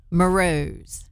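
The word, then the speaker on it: Mruz: Marouz. – It's M-R-U-Z.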